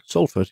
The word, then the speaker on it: Salford: Um, next on the list, Salford. Is that how you pronounce that? Salford, yeah.